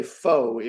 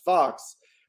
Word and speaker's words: Faux: But the thing about Zeke Fox is his last name is spelled, you would say Faux if you didn't know it was pronounced Fox, which you would only know if you listened to the audiobook.